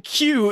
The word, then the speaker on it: queue: You know the word Q, right? Q-U-E-U-E, like you're in a waiting queue or whatever. It says the U-E-U in Q is silent.